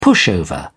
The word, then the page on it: pushover: British English